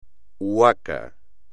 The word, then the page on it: huaca: espagnol